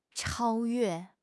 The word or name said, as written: 超越